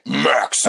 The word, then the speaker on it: Maximus: You're just not saying it right. Maximus. All right, there you go. That's better.